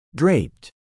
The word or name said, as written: draped